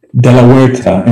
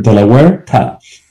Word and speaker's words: De La Huerta: De La Huerta. In Spanish, the H is silent, so go to state Delaware mm-hmm. and throw a ta after it. De La Huerta.